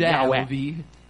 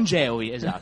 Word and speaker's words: Yahweh: Sì, no, vabbè, in inglese si dice... Cioè, pronuncia in inglese Yahweh. Non Geovi, insomma Yahweh.